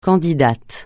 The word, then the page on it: candidate: francais